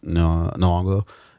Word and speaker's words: Nyong'o: Lupita Nyong'o.